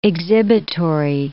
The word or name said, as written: exhibitory